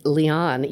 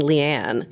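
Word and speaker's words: Liane: It's Leon. You did say it right. Like Leon the, what is it? Leon the Lion? – Maybe. – I had to remember that because I kept saying Leanne.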